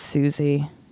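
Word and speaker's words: Siouxsie: And then he appears with, I can't pronounce this, S-I-O-U-X-S-I-E-S-U-X-E and the Banshees, playing drums. – It might be Susie. – Oh, you're right. It's Susie.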